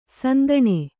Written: ಸಂದಣಿ

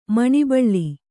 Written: ಮನೋಬಲ

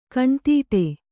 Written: ಕಣ್ತಡೆ

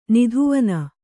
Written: ನಿಧುವನ

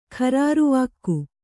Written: ಖರಾರುವಾಕ್ಕು

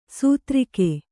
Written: ಸಾತಿರೇಕ

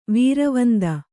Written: ವೀರವಂದ

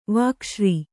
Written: ವಾಕ್ಶ್ರೀ

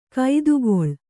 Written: ಕೈದುಗೊಳ್

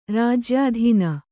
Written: ರಾಜ್ಯಾಧೀನ

